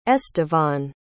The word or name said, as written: estevan